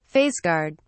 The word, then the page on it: face guard: British English